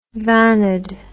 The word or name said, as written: vanad